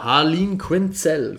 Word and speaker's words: Harleen Quinzel: Genau, Harleen Quinzel, glaube ich, spricht man es aus. – Oder? Richtig, genau.